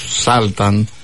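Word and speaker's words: Sultan: Το γράμμα γιου στην αγγλική λέξη Σάλταν βρίσκεται σε κλειστή συλλαβή και προφέρεται το σύντομο αΐ για την ακρίβεια όσο ήχος, όπως στις λέξεις μπας ή καπ.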